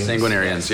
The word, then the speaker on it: sanguinarians: How do you say that? Sanguinarians? – Sagittarius. – What? – Sanguinarians, yes.